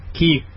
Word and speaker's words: quay: Who says, okay, people, how do you say Q-U... A-Y. – Key. – Key. – Key. Key. – Anyone or everyone who pronounces it quay